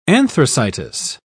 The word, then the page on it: anthracitous: British English